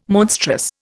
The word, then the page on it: monstrous: Indian